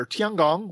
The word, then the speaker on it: Tiangong: Speaking of other things made in China, the Chinese have lost control of their Tiangong 1 space station.